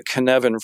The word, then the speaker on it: Cynefin: He wrote an HBR article, I believe it was in 2008, about the Canevin framework, and it's spelled C-Y-N-E-F-I-N.